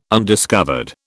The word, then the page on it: undiscovered: British English